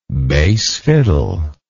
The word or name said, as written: bass fiddle